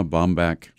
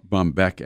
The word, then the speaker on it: Bombeck: Well, have you ever heard of Irma Bombeck? – Sure. Great writer. – Bombeck, actually, it's pronounced.